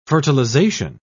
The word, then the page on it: fertilisation: British English